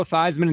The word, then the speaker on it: Theismann: Joe Theismann didn't become Joe Theismann until he was trying to win the Heisman Trophy. So he changed the pronunciation so it rhymed.